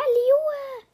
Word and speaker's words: Liyue: Das ist in Liyue. Liyue. Ja, Liyue. Das heißt Liyue.